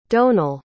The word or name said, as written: domhnall